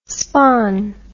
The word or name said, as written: spahn